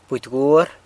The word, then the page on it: budy gwuar: San Lucas Quiaviní